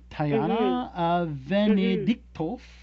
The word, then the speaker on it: Tayana Venediktov: And now you played Stephen Amell's love interest, Tayana Venediktov. Is that right? Did I pronounce that right? – Sounds close. – way to hit the T in the middle there.